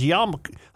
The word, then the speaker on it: yarmulke: A Jewish teacher might be perceived as engaged in demonstrative activity should students see his yarmulke. How do you say that word? – Yarmulke. – Y-A-R-M-U-L-K-E. Yarmulke? – Yeah, just say it quickly. Yarmulke.